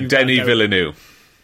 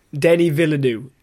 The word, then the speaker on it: Denis Villeneuve: Um, uh, Dennis Villeneuve, I'm going to mispronounce his name. Please, it's uh, Denis Villeneuve. – Denis Villeneuve.